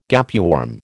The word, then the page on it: gapeworm: American English